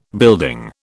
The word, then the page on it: building: British English